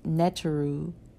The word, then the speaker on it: Netru: The Netteru, pronounced Netteru, N-E-T-R-U, are